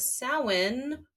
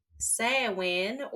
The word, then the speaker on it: Samhain: This episode, we are talking about Samhain or Samhain for those that are trying to pronounce it as it is spelled.